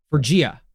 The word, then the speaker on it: Phrygia: Am I saying that right? Phrygia? – Is it so PH? – This is like ancient? – Yeah. – PHY? – Yes. PHY. PHR. Phrygia. PHRYGIA. Where Montanus claimed the heavenly Jerusalem would descend to earth.